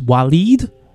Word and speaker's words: Waleed: My guest today is Waleed.